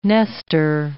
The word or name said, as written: Nestor